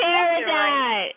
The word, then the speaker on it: Peridot: Is it Peridot? – Ding, ding, ding, ding, ding. It's Peridot.